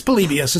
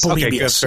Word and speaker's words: Polybius: It's Polybius. As far as I know, it's Polybius.